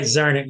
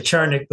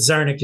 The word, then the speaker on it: Czarnik: Right, Czarnik. Yeah, some people say Czarnik, but Czarnik is the way I use it, right? – Welcome, as usual, to Politics and Right, Tom.